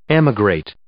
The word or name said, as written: emigrate